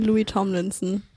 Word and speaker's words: Louis Tomlinson: Jetzt haben wir für euch Sunflowers von Louis Tom Linsner. Den Sonn? Wie wird's ausgesprochen? Kann mir jemand helfen? – Sunflowers von Louis Tomlinson.